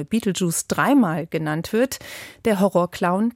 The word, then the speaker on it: Betelgeuse: Denn so hieß der titelgebende Geist in der Horrorkomödie von 1988, angelehnt an den Stern Betelgeuse, der im Englischen Beetlejuice ausgesprochen wird.